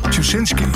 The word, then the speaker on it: Tuschinski: En Tjusit, kan niet uitspreken. In Amsterdam, een heel luxe theater. Tuschinski. Theater.